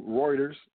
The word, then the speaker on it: Reuters: So, Reuters, today, the Federal Open Market Committee, basically the Fed, they left interest rates unchanged.